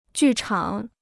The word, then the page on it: 剧场: jù chǎng